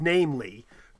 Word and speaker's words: viz: How do you pronounce V-I-Z if you're read out loud to someone? How do you pronounce V-I-Z when you see it? – I would say viz. – I have looked this up, and I've seen people say you should just pronounce it namely.